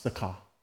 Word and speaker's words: Sychar: So he came to a town in Samaria called Sychar. That's how it's pronounced, Sychar.